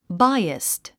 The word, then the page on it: biased: báiəst; バイアスト